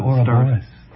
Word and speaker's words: Ouroboros: Maybe you know how to pronounce that word? O-U-R-O. – Aura Borealis? – No, that's the Ouroboros. Ouroboros? – Yeah, okay, Ouroboros. We'll use that.